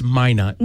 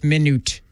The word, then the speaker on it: Minot: It's Minot.